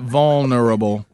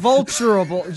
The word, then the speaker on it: vulnerable: What about vulnerable? – It's like, think vulture. Vulnerable. Not fun, like fun. – Fun. – Vulture, like vulture. – Vulnerable. – Vulnerable. Not vulturable, just the V-U-L part. The V-U-L part.